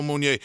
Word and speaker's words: Monier: Is it Monier? Flo? Flo Monier. Again, Long Island. I'm trying my hardest with the pronunciations. – Yeah, you're good.